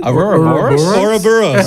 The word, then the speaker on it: Ouroboros: We'll do the fleshlight Arubros. Arubros? Am I saying that right? – Ouroboros.